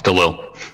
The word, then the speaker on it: Delisle: It's Delisle.